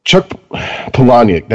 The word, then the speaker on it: Palahniuk: Like Chuck Palahniuk, that's how you pronounce his name, right? – Yeah. – Yes, okay.